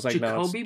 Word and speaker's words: Jakobi: I had to look up the J because in my head, I was like, is it Jacob? – I think it's Jacoby Myers. Jacoby. – Yeah, you're right. It is Jacoby. – And then there's a... – spelled with an I. – Yes, J-A-K-O-B-I.